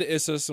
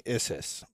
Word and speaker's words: Issus: Like, it's kind of like he fled Issus and was like, all right, we need to build a bigger army.